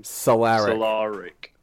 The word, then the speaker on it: Solarik: I mean, even a guy like Klaharik, or how do you say his name? – Solarik.